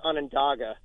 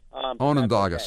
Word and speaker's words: Onondaga: And it's pronounced Onondaga. – Onondaga, sorry.